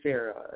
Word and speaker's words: Sera: And Sara is spelled S-E-R-A, so K Sara.